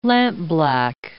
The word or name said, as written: lampblack